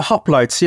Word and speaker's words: hoplites: Am I pronouncing it correctly? – The hoplites, yeah.